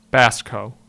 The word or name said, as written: Basco